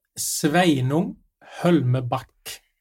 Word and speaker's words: Sveinung Hølmebakk: Ja, Sveinung Hölmeback.